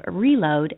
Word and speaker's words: Relode: Has had a soft launch going on for a while, but Matt is the CEO of Reload, and that is spelled R-E-L-O-D-E.